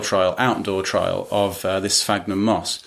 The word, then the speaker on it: Sphagnum: Is it Sphagnum moss? – Sphagnum, that's right.